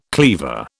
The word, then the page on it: cleaver: British English